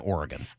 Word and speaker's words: Oregon: When I moved to the West Coast, I found out that it's Nevada and Oregon.